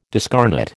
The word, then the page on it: discarnate: American English